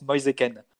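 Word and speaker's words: Moise Kean: je crois qu'on prononce, on prononce mais en Italie, je ne sais pas pourquoi ils prononcent Moiseken.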